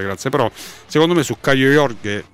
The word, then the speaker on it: Caio Jorge: Quindi un'informazione di servizio che potrebbe esservi utile: la corretta pronuncia di Caio Jorge è lo schio letterale Caio Jorge.